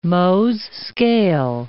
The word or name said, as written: Mohs' scale